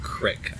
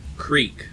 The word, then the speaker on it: creek: Also, I do want to clarify, I do not pronounce that word crick. I pronounce it creek, because that's how it's spelled.